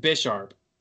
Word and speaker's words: Bisharp: Is it pronounced? It's pronounced Bisharp. 100%.